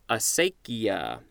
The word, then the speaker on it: acequia: And there's more at the word cephalic, C-E-P-H-A-L-I-C. Next word is, A-C-E-Q-U-I-A. I think this is pronounced acequia, acequia.